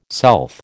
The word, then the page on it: south: American English